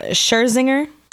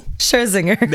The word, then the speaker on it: Scherzinger: Scherzinger. – Okay. That sounds good. So whenever it's time to say her name, we're pointing to you. So look, Nicole Scherzinger.